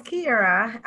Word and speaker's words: Kiarra: So my name is Kiarra.